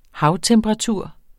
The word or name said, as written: havtemperatur